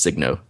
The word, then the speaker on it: Signo: And I've always been I've always said the same thing about this, the Sino DX, and I know it's pronounced Signo. Y'all don't have to tell me that. I just can't say Signo. – Sino is better.